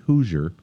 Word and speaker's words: Hoosier: And now, they've taken another step to further the future of their cancer care by joining something called the Hoosier. Is that how you pronounce that? Like Indiana Hoosiers? – Yeah.